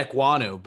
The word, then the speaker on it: Ekwonu: I just say Ekwonu, but I mean. – Okay, yeah. He was beating Ekwonu on some plays.